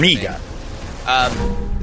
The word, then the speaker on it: Farmiga: I'm not sure how to pronounce it. – Farmiga.